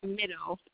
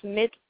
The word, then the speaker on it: Mitto: It is Mitto, M-I-T-T-O, Mitto, or Mitu, however you choose to pronounce it. It's Mitu.